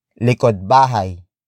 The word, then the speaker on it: likod bahay: And that is likod bahay, likod bahay, likod bahay. Likod bahay is spelled as L-I-K-O-D hyphen B-A-H-A-Y.